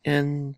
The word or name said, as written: en